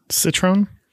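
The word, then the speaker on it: Citroën: Citroen.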